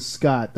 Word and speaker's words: Scott: Hi, my name is Scott. That's spelled S-C-O-T-T.